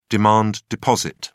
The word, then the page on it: demand deposit: British English